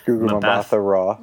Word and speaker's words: Gugu Mbatha-Raw: Gugu Mbatha-Raw.